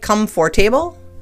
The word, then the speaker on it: comfortable: It looks like comfortable, but it's comfortable.